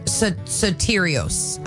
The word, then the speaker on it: Soterios: Um, Soterios. I should be saying it right because I am Greek.